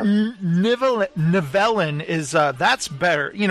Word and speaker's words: Nivellen: Yeah, the beast's name is Nivellen, I believe if I'm saying that right. – Uh, he pronounced it Novellin.